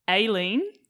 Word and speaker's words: Aileen: We're speaking, for those of you out there who don't know, with Aileen. – Yes. – Is how you pronounce it.